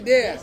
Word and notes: dea: Ansus

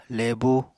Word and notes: lebu: apatani

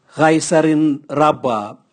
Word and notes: gaisarin rabwa: Raga

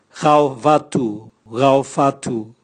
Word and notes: gaovatu: Raga

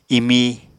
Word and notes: imi: apatani

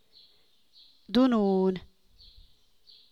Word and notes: Quialana: American English

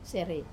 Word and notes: serei: Wamesa

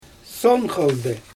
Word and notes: son xaldɯ: Xyzyl

